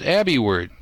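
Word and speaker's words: AbiWord: Well, right smack in the middle of that range is AbbeyWord. That's spelled Alpha, Bravo, India, Word.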